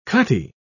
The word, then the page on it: cutty: British English